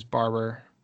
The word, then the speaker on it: Barbour: Do you know, is it kind of the classic, is it Barber or Barbour? I always thought it was Barbour. – I've always heard it pronounced as Barber, but it's the wax jacket.